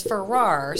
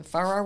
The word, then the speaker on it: Farrar: Yeah, it's Farrar. So, you know, the ending scene of A Christmas Story when the hound dogs eat the turkey and they go to the Chinese restaurant and they're standing around going, Farrar. That's how you pronounce my name.